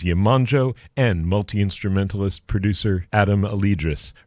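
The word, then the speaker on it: Alydrus: That's A-L-Y-D-R-U-S. Adam Alidrus, I believe is the pronunciation.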